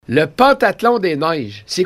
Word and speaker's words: pentathlon: On dit, est un peu, moi j'avais l'habitude aussi de dire le pentathlon, on dit pentathlon. – Pentathlon. – C'est bien mentionné. – Pentathlon. – Mentionné pain comme le pain, P-A-I-N, tathlon.